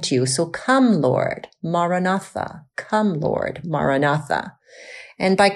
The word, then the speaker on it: Maranatha: So as we say it gently in four syllables. Maranatha. Maranatha.